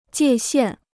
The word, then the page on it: 界限: jiè xiàn